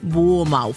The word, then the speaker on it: Bournemouth: Geschrieben wird der Küstenort Bournemouth. Aussprechen tun die Engländer das Bournemouth.